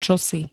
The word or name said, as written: čosi